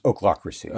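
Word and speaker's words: ochlocracy: I think it's pronounced oaklocracy.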